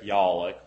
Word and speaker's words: Yollick: May it please the court, my name is Eric Yollick, Y-O-L-L-I-C-K, and I'm here representing...